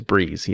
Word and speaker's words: Brees: That is pronounced Drew Brees? – It is Brees, yes. – Brees.